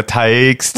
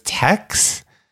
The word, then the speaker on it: Taix: We're like, so let's go to Tikes. – Wait, what is it? – It's spelled T-A-I-X. And I actually think I read an article, and maybe I'm wrong, in LA Weekly. It said it's Tex.